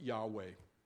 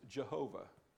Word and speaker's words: Yahweh: It's usually pronounced Yahweh. In some languages today, it's pronounced Jehovah.